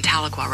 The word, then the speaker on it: Tahlequah: 64 degrees in Tahlequah right now. – That's how you pronounce it. Okay, cool. I thought it was Tahlequah. Tahlequah. It sounds like Metallica, but it's Tahlequah.